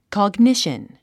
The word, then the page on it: cognition: kɑɡníʃən; コォグニシャン